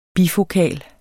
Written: bifokal

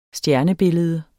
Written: stjernebillede

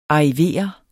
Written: ARRIVER

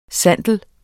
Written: sandel

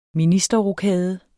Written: ministerrokade

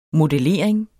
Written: modellering

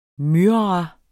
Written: MYRRAEN

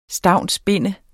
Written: stavnsbinde

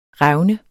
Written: revne

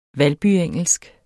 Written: valbyengelsk

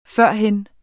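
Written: FØRHEN

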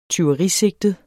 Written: tyverisigtet

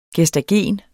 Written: gestagen

